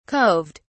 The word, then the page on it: coved: British English